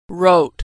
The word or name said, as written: wrote